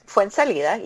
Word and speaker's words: Fuenzalida: Lionel Messi scored a penalty after, you're going to have to help with this pronunciation, Fuenzalida? – That was not bad. Fuenzalida, yeah.